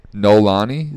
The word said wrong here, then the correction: Noelani: Noelani. – Noelani. – Wow. It's spelled like Noel, A-N-I.